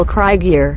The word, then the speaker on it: Krieger: En realidad nunca supe cómo se pronuncia. – Axel Krieger. Axel Krieger.